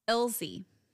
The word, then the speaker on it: Ilse: Ilse. Because it's not pronounced like the Spanish version.